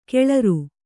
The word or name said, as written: ಕೆಳರು